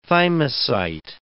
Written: thymocyte

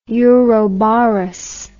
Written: ouroboros

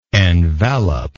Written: enveloper

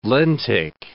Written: lentic

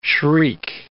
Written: shriek

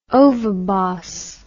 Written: ovibos

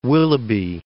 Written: Willoughby

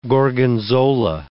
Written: Gorgonzola

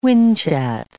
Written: whinchat

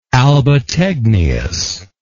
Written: albategnius

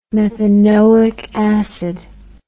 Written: methanoic acid

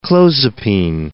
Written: clozapine

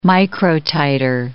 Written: microtiter

